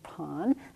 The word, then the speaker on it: Pan: Pan, if you want to think of how to spell it, P-A-N, pronounce it Pan.